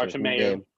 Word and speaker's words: Bartomeu: There's a guy named Joseph Bartomeu, or Bart, I'm probably messing up the pronunciation. – Bartomeu.